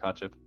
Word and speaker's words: Kotcheff: I think that's how you say it, Kotcheff.